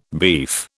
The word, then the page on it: beef: British English